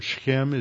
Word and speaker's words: Shechem: And then Abimelech is filled with humility, and he goes, he lives in Shechem, or Shechem as it's pronounced in Hebrew. Shechem, you have to really get that guttural in there.